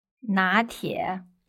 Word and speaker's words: 拿铁: 拿铁。 – Okay. Second tone, third tone.拿铁, and that's just a transliteration, so it sounds like latte.